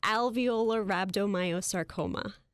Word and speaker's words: alveolar rhabdomyosarcoma: Alveolar rhabdomyosarcoma.